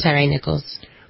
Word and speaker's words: Tyree Nichols: Was it Tyree Nichols? – I think it was. – Tyree Nichols.